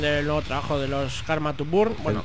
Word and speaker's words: Karma to Burn: Karma to Burn.